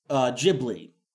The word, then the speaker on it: Ghibli: Ghibli is the actual pronunciation of the word.